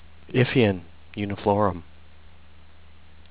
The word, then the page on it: Ipheion uniflorum: if'-ee-on yew-knee'-flor-um